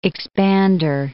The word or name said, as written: expander